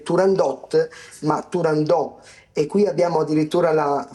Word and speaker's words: Turandot: che dice proprio espressamente che non si dice, non si pronuncia con la T finale, ma invece è realmente Turandò.